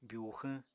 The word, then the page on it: byuxwñ: apatani